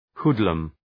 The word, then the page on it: hoodlum: ‘hu:dləm